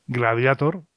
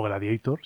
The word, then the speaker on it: Gladiator: Gladiator, o Gladiator, si lo pronunciamos más a la inglesa.